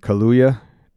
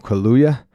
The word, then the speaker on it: Kaluuya: I think I mispronounced his name. Kaluuya. Daniel Kaluuya. He is playing a character, a horse wrangler, by the name of Otis Jr., a.k.a.